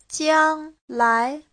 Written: jiang1lai2

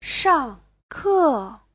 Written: shang4ke4

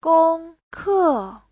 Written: gong1ke4

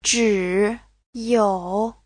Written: zhi3you3

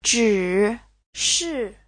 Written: zhi3shi4

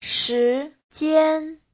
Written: shi2jian1

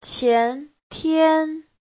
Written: qian2tian1

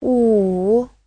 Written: wu3

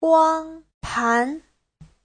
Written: guang1pan2

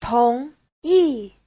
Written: tong2yi4

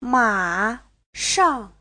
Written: ma3shang4

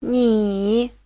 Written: ni3

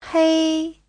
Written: hei1

